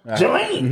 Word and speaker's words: Ghislaine: We only mentioned an Epstein because of Ghislaine.